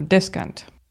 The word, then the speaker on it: descant: Okay, the stress is on the first syllable, descant.